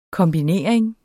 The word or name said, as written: kombinering